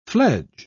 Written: fledge

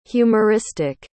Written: humoristic

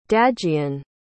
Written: dagian

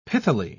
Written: pithily

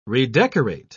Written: redecorate

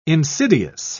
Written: insidious

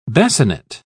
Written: basinet

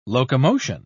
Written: locomotion

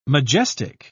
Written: majestic

